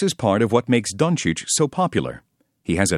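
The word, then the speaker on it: Dončić: Luka, Luka, Doncic, Doncic.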